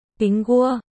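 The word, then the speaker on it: ping gua (苹果): Bởi vì trong tiếng Trung, quả táo được phát âm là ping gua, đồng âm với chữ bình trong đêm bình an.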